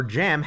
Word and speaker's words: jamb: Another difference is that a door frame has a silent E and a door jamb has the very rare silent B.